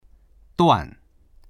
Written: duan4

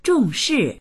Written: zhong4shi4

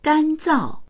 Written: gan1zao4

